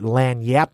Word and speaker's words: lagniappe: and I put lanyap on the side. – Lanyap. – It's actually spelled lagniappe, but it's pronounced lanyap, and it's like a slang phrase.